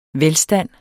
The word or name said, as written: velstand